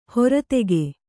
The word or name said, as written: ಹರಿತಕ